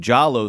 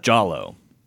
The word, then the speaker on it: Giallo: Representing the art house, it's Dario Argento's 1977 cult classic of the Italian Giallo school of horror. Did I say that right? – Oh, yeah. – Giallo? – I'm sure... No, Giallo's fine.